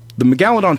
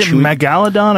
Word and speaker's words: megalodon: Is it megalodon or megalodon? How do you say it? – It depends. Your emphasis can be on a different syllable.